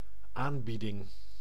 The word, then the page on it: aanbieding: /ˈaːn.bi.dɪŋ/; BE; NL